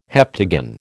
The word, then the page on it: heptagon: American English